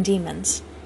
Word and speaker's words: daimon: and others on demons. All agree that the word devil in the text, they are the spirits of devils, is the Greek word daimon, commonly pronounced demon.